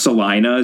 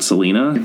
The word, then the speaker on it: Selina: But By the way, the only reason I know it's pronounced Celina is because that line was lifted from the book and put into the adaptation, and the actress pronounced it Celina, not Selena.